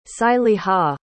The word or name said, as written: saliha